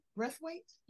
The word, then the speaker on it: Braithwaite: Braithwaite? – Yeah.